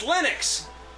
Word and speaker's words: Linux: It's Linux. It's very. – But the guy's name is Linus. – Yeah, we don't care about that. He pronounces it Linux, so we all pronounce it Linux.